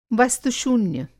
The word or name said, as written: वस्तु-शून्य